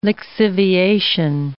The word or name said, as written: lixiviation